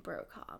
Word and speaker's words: Brokaw: Search my name, Blythe Brokaw. – Can you spell that for people? Because obviously it's not a typical. – It's Blythe, B-L-Y-T-H-E, mm-hmm. and Brokaw, B-R-O-K-A-W.